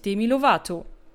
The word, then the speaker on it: Demi Lovato: Und da gibt es auch gerade eine sehr bekannte Person, die sich erst vor kurzem geoutet hat, nämlich Demi Lovato.